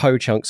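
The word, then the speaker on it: Hoocąk: So, and this is, so yeah, so in Ho-Chunk, it's in Ho-Chunk, so it is spelled H-O-O-C-A with a little tilde at the on the bottom of it, a little tail, K, but it's pronounced Ho-Chunk. So in Ho-Chunk language,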